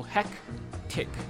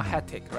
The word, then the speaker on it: hectic: So H-E-C-T-I-C. So hectic, not hectic, right? So there's two stops. Hectic, hectic. So you need to pay a little bit of attention to the pronunciation.